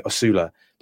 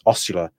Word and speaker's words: Osula: I'm actually not 100% certain how you pronounce his surname, because I had been saying Osula, but I was listening to Five Live commentary recently, and it was Ostula. So I'm going to go with that.